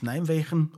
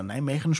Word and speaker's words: Nijmegen: bis Nijmegen, oder Nijmegen spricht man es, glaube ich, aus.